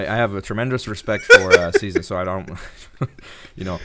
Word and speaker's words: SZA: It's like saying scissors. Just say SZA. – SZA? Is that SZA? – There you go.